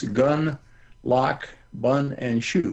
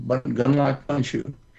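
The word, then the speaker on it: Gundlach Bundschu: You just string together four short words, gun, lock, bun, and shoe. Gun, lock, bun, shoe.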